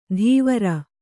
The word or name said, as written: ತೀವ್ರ